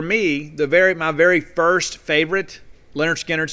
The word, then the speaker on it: Lynyrd Skynyrd: Now, pronounced Lynyrd Skynyrd, I had that on cassette.